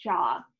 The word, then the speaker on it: Zhao: I believe her last name is pronounced Jaw. I saw that on a Hollywood Reporter article.